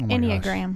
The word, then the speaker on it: Enneagram: Oh Enneagram.